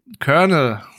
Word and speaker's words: Colonel: Außerdem haben wir noch mit dabei Alison Janney als äh, Col oh Gott, wie spricht man das? Colonel. Colonel, danke.